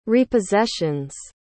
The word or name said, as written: repossessions